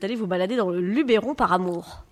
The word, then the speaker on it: Luberon: Vous avez raison, c'est le Luberon.